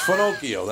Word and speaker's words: Finocchio: It's Finocchio. That's how you pronounce it.